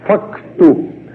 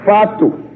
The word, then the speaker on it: facto: E também pronunciam facto. No ano passado, quando estive lá em Portugal, fazendo conferências, eu tive que tomar muito cuidado para não dizer fato, como nós estamos habituados a dizer.